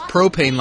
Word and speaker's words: Pro-Pain: It's pronounced propane like the gas, but it's spelled pro-pain, P-A-I-N.